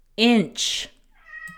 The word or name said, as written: inch